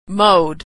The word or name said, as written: mowed